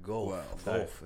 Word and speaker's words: Guelph: It's like sounds like you're puking. It's like well, you know, it's like Ralph. It's spelled a G U E L P H. – Guelph.